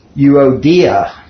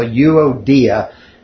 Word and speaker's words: Euodia: So this is euodia, okay? The emphasis is on the third syllable. So euodia.